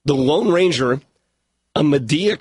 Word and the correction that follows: Madea: Christmas, or Media, however you want to pronounce Madea.